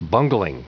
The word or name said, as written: bungling